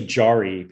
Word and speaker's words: Jeroen: Well, you know, it's mostly Jari because uh, that's the English pronunciation, but it is Dutch.